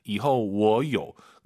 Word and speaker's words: 以后: 以后我有. 그리고 호가 아니라 오유는 어예요. 이后. 이后 이 발음을 꼭 기억하셔야 됩니다.